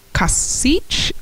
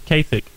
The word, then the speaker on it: Kasich: Then we have Republican John Kasich. How do you say that? Something. – No, it's Kasich.